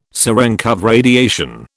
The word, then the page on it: Cerenkov radiation: British English